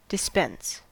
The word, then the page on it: dispense: American English